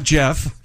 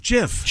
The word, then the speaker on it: GIF: Not Jeff. GIF.